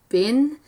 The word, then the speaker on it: Bien: But the Germans, they have a word for a bee colony, and I hope I'm not butchering the pronunciation of this, but it's Bien, and it's spelled B-I-E-N.